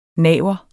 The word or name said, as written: naver